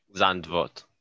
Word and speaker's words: Zandvoort: Zandvoort.